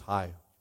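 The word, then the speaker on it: Ai: I think, yeah, it's just high. It's like H-I. That's how you pronounce that. High. Thank you. I was like, how do you, I'm like, oh, there's artificial intelligence in the West. Is anybody, or in the East, sorry. No. Hi. That's how you say it.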